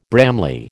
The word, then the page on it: Bramley: American English